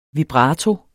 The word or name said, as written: vibrato